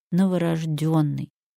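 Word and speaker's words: новорождённый: Правильно, конечно же, новорожденный, потому что гласное «ё» — показатель ударения.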